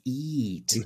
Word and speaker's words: EEAT: I believe the proper pronunciation is EAT.